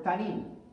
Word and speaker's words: tannin: When you study this, the word serpent here is the Hebrew word tanin. It's spelled T-A-N-N-I-N.